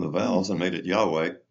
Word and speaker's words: Yahweh: And then when a mistake, when they came from German to English, they didn't know that a J in German is pronounced like a Y. And so they pronounced it now Jehovah. But Jehovah is really just a transliteration, an error, going from Yahweh to Jehovah.